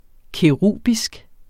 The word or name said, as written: kerubisk